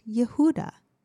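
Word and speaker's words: Yehuda (יהודה): In Genesis 29:35, we read that Leah gave birth to her fourth son and named him Judah, which in Hebrew is pronounced Yehuda.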